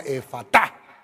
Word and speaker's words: Efata: Y levantando los ojos al cielo, gimió y le dijo, Efata. Algunas versiones ponen el acento en la última Efata.